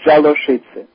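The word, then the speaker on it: Działoszyce: I am descended from a Polish family from, oh, my gosh, I can't pronounce this, D-Z-I-A-L-O-S-Z-Y-C-E. – Jaloszice. – Okay. And the Polish pronunciation is Jaloszice. – Jaloszice. And the name that he's looking at is K-A-T-O-L-I-K, Catholic.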